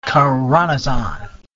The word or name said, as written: khoronzon